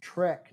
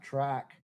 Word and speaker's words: Trek: It's pronounced Trek, not track.